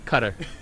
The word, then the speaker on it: Qatar: Qatar. – Qatar.